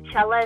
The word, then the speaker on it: Chela: So my my Instagram is Official Chela Music. Chela is spelled with one L, so C-H-E-L-A, so Official Chela Music.